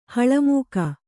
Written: ಹಳಮೂಕ

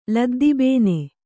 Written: ಲದ್ದಿಬೇನೆ

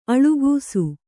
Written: ಅಳಕಿಸು

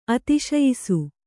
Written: ಅತಿಶಯಿಸು

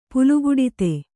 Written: ಪುಲುಗುಡಿತೆ

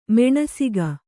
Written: ಮಾನಸಿಕ

